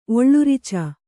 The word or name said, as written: ಒಳ್ಳುರಿಚ